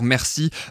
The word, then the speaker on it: Mercy: Alors Bilal Hassani fera-t-il mieux que la 13e place de Madame Monsieur, le duo euh, qui chantait sa chanson Mercy sur les migrants euh, l'année dernière Merci euh, pour la prononciation française, c'est peut-être mieux.